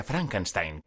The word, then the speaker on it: Frankenstein: Marty se pronuncia Frankenstein, como en alemán.